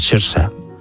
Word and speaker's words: Saoirse: que como es gaélico es Saoirse. Es como se pronuncia.